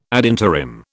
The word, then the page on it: ad interim: British English